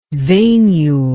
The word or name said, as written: veinule